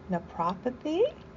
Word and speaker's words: naturopathy: She is a doctor of alternative medicine and naturopathy.